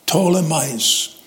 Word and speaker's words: Ptolemais: The P is silent there, Ptolemais.